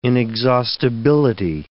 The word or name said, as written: inexhaustibility